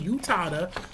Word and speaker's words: Utada: It's spelled U-T-A-D-A, Yutada.